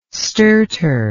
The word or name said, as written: stertor